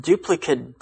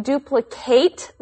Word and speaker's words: duplicate: That's because the noun and verb are pronounced differently. The noun is pronounced like a short I, duplicate. – So if I have a copy of a photograph, then I have a duplicate? – Right.